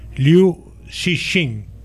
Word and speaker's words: Liu Cixin: Bueno, pues esta serie, que la podéis ver en Netflix, está basada en la novela de ciencia ficción del escritor chino Liu Cixin.